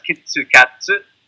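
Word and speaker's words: Kitsukatsu (キットカット): So, Japanese point for you guys, for your listeners out there, for a Japanese language point, Japanese pronounce Kit Kat as Kitsukatsu. Kitsukatsu is good luck, or you can do it.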